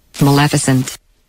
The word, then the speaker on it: Maleficent: So I also got some official pronunciation guides. Maleficent. Let's find out what they said. – Maleficent. – Maleficent. – I mean, come on. – This is the officials.